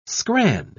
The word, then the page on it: scran: British English